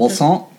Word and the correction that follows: croissant: It's not Wasson. It's croissant.